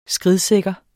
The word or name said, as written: SKRIDSIKKERT